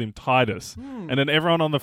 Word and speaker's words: Tidus: Yeah, Tidus sounds so much better. Because Tidus sounds like it could be a Romany sounding name, and that's definitely what they're going with how it's pronounced Tidus.